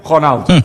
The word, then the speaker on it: Ronaldo: Cristiano Ronaldo. – Voilà, Cristiano Ronaldo. Ne prononcez pas le Ronaldo comme vous faites, Sébastien, habituellement.